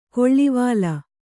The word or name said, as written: ಕಳವಲು